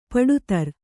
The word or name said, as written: ಪಡತರ